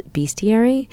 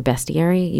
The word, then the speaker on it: Bestiary: and it's called Bestiary or Bestiary. You can pronounce it either way.